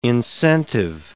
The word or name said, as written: incentive